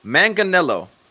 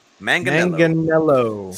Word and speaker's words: Manganiello: Sill helped us with the pronunciation. Manganiello. – Manganiello. – I was right. Jello. Manganiello Jello. – Manganiello.